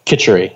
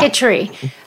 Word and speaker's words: Khichdi: And one time, what I did is, let me know if I'm pronouncing this correct or how it is. Khichdi or Khichari? – Khichdi, yeah. – Khichdi.